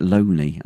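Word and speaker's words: Lownie: I keep saying Lowney, and he's pronounced it Loney.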